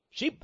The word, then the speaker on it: Pugh: And boy, Dr. Joy Jeffries Pugh, that's spelled P-U-G-H. P-U-G-H. I don't know why it's not pug, but it's just not, okay? I guess it's French or something, you know, one of those. It should be pug, shouldn't it, Sandra? P-U-G-H? Dr. Joy, no? You think it should be Pew? How do you pronounce it? Pew. See there?